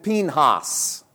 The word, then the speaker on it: Phinehas: The prophet says, this shall be a sign to you that will come upon your two sons, on Hophni and Phinehas. It's the proper Hebrew pronunciations.